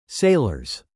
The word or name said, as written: sailors